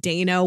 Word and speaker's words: Dano: And even though I know his name is pronounced Dano, when you look at it, it looks like Dan.